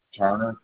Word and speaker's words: Turner: Turner, T-U-R-N-E-R.